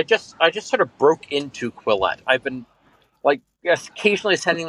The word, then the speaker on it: Quillette: Quillette.